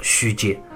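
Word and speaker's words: 吁嗟: 也就是左边都加上一个口字旁，读作虚阶。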